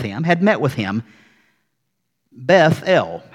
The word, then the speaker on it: Bethel: So, house of God, Beth-El, or as we say, Bethel. Jacob names this place Bethel. And then Jacob and his family, they journey on from Bethel,